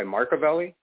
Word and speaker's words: Machiavelli: It's like Machiavellianism, but my name's Mark. So I go by Machiavelli.